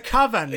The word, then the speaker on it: coven: The coven, because it rhymes with oven.